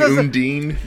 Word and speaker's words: Undine: I was making sure the Udine, right? That's the term for what they do. – Yes, that is how they say it. I don't think that's how you would say that in our language. – Yeah, I kept saying Undine, but yes.